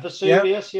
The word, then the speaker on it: Vesuvius: Vesuvius. V-S-V-S. Is that the right way you say it? – Yeah, Vesuvius. Yeah, I'll go for that.